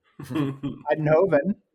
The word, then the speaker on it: Eindhoven: Eindhoven.